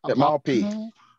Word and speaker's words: Maupay: And on the day, Mapa was, I don't know if I'm pronouncing his name pretty well. – Maupay. Maupay.